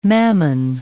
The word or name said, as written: mammon